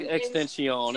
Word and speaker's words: XXXTentacion: Extension. It's like a fancy-ass way of saying extension. – Yeah, so it's like saying extension, but with three X's and everything else is misspelled.